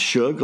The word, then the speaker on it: Shug: You pronounce Shug like you're saying the first syllable of the word sugar, and Jordan like Jordan, which means it sounds not a bit like it looks,